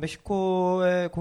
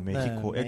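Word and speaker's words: México: 그러니까 우리는 멕시코라고 발음을 하는데 사실 그 현지 지명은 메히코입니다. – 메히코. 네, X가 네. 아, 히읗 발음 나기 때문에 그렇습니다.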